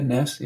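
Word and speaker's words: Næss: I see Martha Nussbaum here, and Arne Ness. I'm not sure if I'm pronouncing his surname correctly. – That's right. Yeah, Ness.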